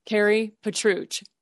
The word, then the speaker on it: Kari Petruch: Kari Petruch.